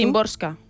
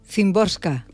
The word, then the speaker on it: Szymborska: Szymborska. – Szymborska.